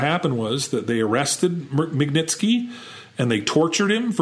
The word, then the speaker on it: Magnitsky: who was a Russian attorney by the name of Sergei Magnitsky. Magnitsky.